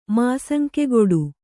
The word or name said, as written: ಮಾಸಂಕೆಗೊಡು